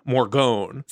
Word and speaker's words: Morgonn: Now, Morgone is spelled M-O-R-G-O-N-N, Morgone.